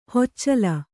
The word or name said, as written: ಹಚ್ಚೆಲ